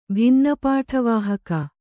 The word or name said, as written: ಭಿನ್ನಪಾಠವಾಹಕ